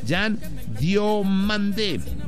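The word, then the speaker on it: Yan Diomandé: Ah, no, de hecho se pronuncia Jan Diomandé. – ¿Mandé? – Exacto, Jan Diomandé.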